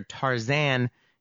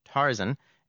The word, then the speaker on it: Tarzan: The more familiar Tarzan is actually a byproduct of the 1931 MGM film, Tarzan the Ape Man. For whatever reason, the producers decided to ignore the fact that Burroughs himself pronounced the name Tarzan and that pronunciation had been used in early radio shows that Burroughs had been involved with.